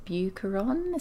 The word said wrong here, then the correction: Bouchercon: is that right? – Oh, Bouchercon. – Bouchercon. Yes.